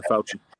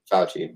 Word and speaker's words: Fauci: Fauci was, however you pronounce his name. – Yeah, Fauci. – Fauci.